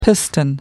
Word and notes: piston: American English

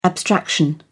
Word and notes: abstraction: British English

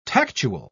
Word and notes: tactual: British English

